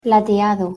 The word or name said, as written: plateado